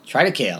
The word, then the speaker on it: Triticale: Gluten is a protein found in wheat, barley, rye, and triticale? How does you say that word? It's a combination of wheat and rye. – It looks like triticale. – Triticale, all right.